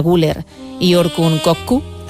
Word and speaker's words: Güler: Creo que Arda es Arda Güler. – Ah, Güler. Ah, Güler.